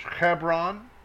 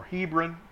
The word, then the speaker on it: Hebron: Kiriath Arba, which is Hebron, or Hebron, English pronunciation, where sojourned there Abraham and Yitzchak,